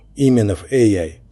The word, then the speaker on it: Пименов AI: Пименов AI с ударением на «и» и без лишней паузы внутри AI.